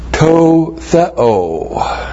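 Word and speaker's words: To theo (τῷ θεῷ): From pistewo, and then the Tetragrammaton is translated by Paul with to theo. That's the dative of theos with the definite article. T long O, T H E long O. To theo, in the God.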